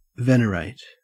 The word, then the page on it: venerate: Australian English